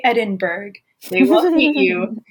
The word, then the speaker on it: Edinburgh: So I went to Vienna and went to Edinburgh. Is that how you pronounce it? – Yeah. – Nice. Yeah. I thought it was Edinburgh at first. – I thought it was Edinburgh too. And then luckily Brad, who was one of the other people who we interned with, he had gone the year before.